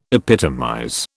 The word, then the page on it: epitomize: British English